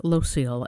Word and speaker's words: Loscil: Locile, L-O-S-C-I-L.